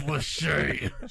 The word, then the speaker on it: Lachey: It's Lachey.